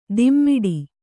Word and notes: ದಿಮ್ಮಿಡಿ: dimmiḍi

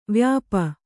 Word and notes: ವ್ಯಾಪಿ: vyāpi

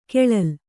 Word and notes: ಖಳಲ್: khaḷal

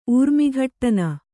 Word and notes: ಊರ್ಮಿಘಟ್ಟನ: kannada

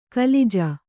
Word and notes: ಕಲಿಜ: kalica

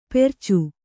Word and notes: ಪೆರ್ಚು: percu

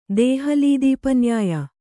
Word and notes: ದೇಹಲೀದೀಪನ್ಯಾಯ: dēhalī dīp nyāya

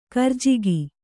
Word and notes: ಕರಿಜಿಗಿ: karijigi

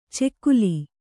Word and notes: ಚೆಕ್ಕುಲಿ: cekkuli

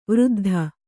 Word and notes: ವೃದ್ಧೆ: Kannada

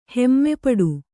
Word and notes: ಹೆಮ್ಮೆಪಡು: hemme paḍu

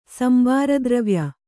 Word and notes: ಸಂಬಾರದ್ರವ್ಯ: kannada

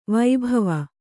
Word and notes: ವೈಭವ: Kannada

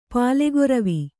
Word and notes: ಪಾಲೆಗೊರವಿ: pālegoravi

